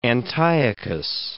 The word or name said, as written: Antiochus